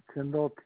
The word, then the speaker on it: Tyndall: Last name is Tyndall, T-Y-N-D-A-L-L.